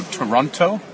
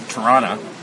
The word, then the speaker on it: Toronto: Which, by the way, is pronounced with two T's. Just so that you know, Toronto has two T's. Unless you're from Toronto, in which you say Toronto.